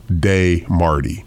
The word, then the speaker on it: Desmardi: You get your tickets for D Marty, and it's spelled DESMARDI, pronounced Day Marty.